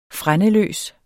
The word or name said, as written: frændeløs